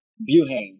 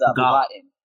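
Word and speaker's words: Buhain: I'm originally from the Philippines, but ever since I moved to America, everyone's been pronouncing it Buhane, so I just ran with it. – Okay, so we pronounce it Buhane, we being Americans, but with Filipino, is it Tagalog or what's the language? – It's Tagalog, yeah. It's Bukatin.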